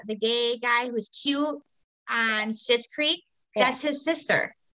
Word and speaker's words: Schitt's Creek: She's from Schmitz Creek. Is that how you say it? – Oh, Schitt's Creek.